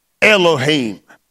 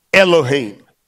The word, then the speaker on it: Elohim: Elohim. It is, if you spell it like it sounds, it's E-L-O-H-E-E-M, Elohim.